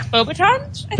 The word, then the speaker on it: Beauxbatons: Beauxbatons? – Thank you.